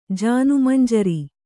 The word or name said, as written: ಜಾನುಮಂಜರಿ